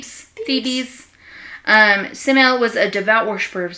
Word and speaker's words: Semele: He slept with Semele, or Semele, however you want to pronounce it. It's S-E-M-E-L-E. The princess of Thebes. T-H-E-B-S. – Thebes. – T-H-E-B-E-S.